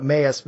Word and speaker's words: Maeus: I was going to mispronounce it Mies, but Maeus.